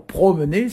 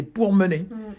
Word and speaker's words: pourmener: Au lieu de dire « promener », c'est « pourmener ».